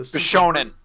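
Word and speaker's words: Bishonen (美少年): You know, is it Bishuman? Is that how you pronounce it? Where the Bishonen. – Bishonen, yep.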